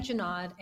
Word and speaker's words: Junod: Betty Junod, and I work at VMware. – I've been mispronouncing it this whole time. – No. Well, technically, so my... Technically, the name comes from it's French Swiss origin.